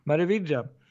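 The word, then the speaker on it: Meraviglia: Eh, MECs nya skepp, MEC Merividja, tror jag man uttalar ja, det.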